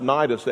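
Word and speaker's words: Cnidus: It's pronounced Nidus. It has a C that's silent on the front.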